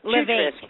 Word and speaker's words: Levesque: Levesque. Pretty close.